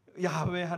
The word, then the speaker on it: 야훼: 이 말씀을 받아 썼던 사람들이 정말 두렵고 떨리는 마음으로 중간에 야훼 하나님이 원래는 이제 우리 성경은 여호와로 번역했지만 사실 발음이 틀렸거든요.